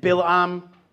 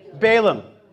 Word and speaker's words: Balaam: Do you remember the biblical story of Balak, King Balak and Bilam? Do I pronounce that in Christianese correctly? Balaam.